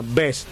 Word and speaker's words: Best: Best, tiene razón, discúlpeme la pronunciación.